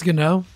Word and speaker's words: Ngannou: Ngannou, is that how you say it? – I think it's Ganou. – Oh, Ganou. I always say that wrong. Ganou. Ganou. – Francis? – Yeah, Francis Ganou. I've heard Ngannou too, but Ganou, I'm sure, is right.